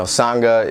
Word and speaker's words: Sangha: I mean, the first thing I asked you was how to pronounce it. – You know, Sangha is a Tibetan word for community.